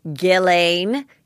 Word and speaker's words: Ghislaine: We have a lot to cover, lots of updates, and then I have an expert who has been covering the Ghislaine, is how she says the English pronounce it, Maxwell trial, which she really helps explain what's going on, and it's absolutely riveting.